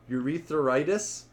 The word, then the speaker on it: urethritis: Urethra, u, urethritis. Is that the right way to say it? Yep acute due to gonococcus gonorrhea.